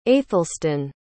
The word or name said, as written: aethelstun